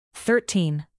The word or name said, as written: thirteen